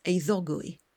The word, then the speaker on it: Eiddogwy: In Welsh, I know how to pronounce it. It's Eiddogwy.